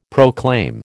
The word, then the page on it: proclaim: American English